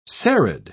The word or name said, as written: serried